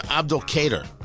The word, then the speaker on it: Abdul Kader: I think I would pronounce his first name Abdul Kader. A-B-D-U-L-K-A-D-E-R, last name Sinno, S-I-N-N-O.